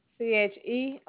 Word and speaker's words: Cherae: Cherae.